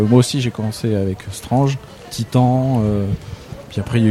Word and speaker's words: Strange: Et on reconnaît les vrais fans, c'est qu'ils ne disent pas Strange, ils disent bien Strange. Ah oui, voilà, Strange. Cette prononciation très française qui est notre manière à nous, un peu comme le gendarme à New York, de percevoir l'Amérique.